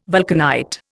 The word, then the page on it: vulcanite: Indian